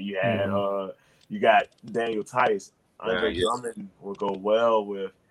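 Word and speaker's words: Theis: Who's number 27? – Daniel Tice. – Yeah, Tice, yes.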